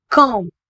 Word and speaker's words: calm: For example, காம், CALM. இதனை காம் என உச்சரிக்க வேண்டும். L silent.